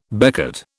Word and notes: becket: British English

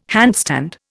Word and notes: handstand: Indian

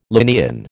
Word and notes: Linnaean: American English

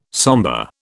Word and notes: sombre: British English

